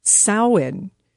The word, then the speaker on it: Samhain: Well, Samhain used to be the name of a punk band, but how you say it actually is Samhain. So it looks like Samhain, but it's Samhain, and that is a Celtic festival where Halloween first began, and you can learn about the ancient Halloween mask-making traditions.